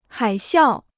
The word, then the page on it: 海啸: hǎi xiào